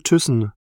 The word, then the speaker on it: Thyssen: Mein Name ist Axel Thyssen. Das schreibt man T-H-Y-S-S-E-N.